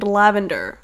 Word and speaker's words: LXVNDR: This artist is named Lavender. I know how to pronounce that one, but it is spelled very strangely. It's LXVNDR.